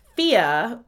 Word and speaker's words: Fiadh: Number three is Fia, but it's spelled F I A D H.